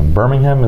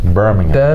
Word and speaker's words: Birmingham: Birmingham, is that the correct pronunciation? – Birmingham. I think if you're there, it's Birmingham, but I call it Birmingham.